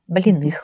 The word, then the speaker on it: Блиных: Правильное ударение, да, поставила? – Да, Блиных.